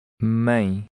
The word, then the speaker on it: mãe: The E sounds like A. – Mãe.